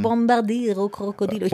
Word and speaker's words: Bombardiro Crocodilo: Ja, yeah, also Bombardiro Crocodilo. – Du musst es italienisch aussprechen. – Bombardiro Crocodilo.